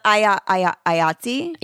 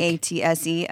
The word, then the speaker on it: IATSE: IOSCE? No, I'm saying that wrong. How do you say it? – IATSE? – Yeah. They're about to strike. – IATSE. Oh, okay.